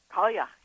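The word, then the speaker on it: Cailleach: Well, let's talk about the old woman goddesses who, I may mispronounce this, but I'll do it my best, was called Kalyakh. – Kalyakh, yeah, the Kalyakh. – Oh, Kalyakh, okay.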